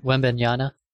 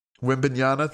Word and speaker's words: Wembanyama: That, I think everyone knows that the Spurs are going to draft Victor, how do you say his last name? – Wembanyama. – Wembanyama, thank you.